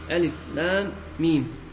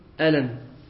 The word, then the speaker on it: الم: وهذه الحروف التي افتتح الله بها بعض سور كتابه يسميها العلماء الحروف المقطعة، وقد ذكرت غير مرتبة، وتنطق هذه الحروف في القرآن بأسمائها ألف لام ميم ونحو ذلك، ولا تنطق كلمة مركبة من الأحرف الثلاث، فلا تقول في ألف لام ميم ألم، ولا في طاء سين ميم طاسم.